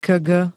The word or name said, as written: kg